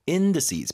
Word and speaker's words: indices: 어쨌든 index 그다음에 indices 발음 맞죠? – Indices.